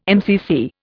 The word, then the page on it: MCC: Indian